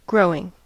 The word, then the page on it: growing: American English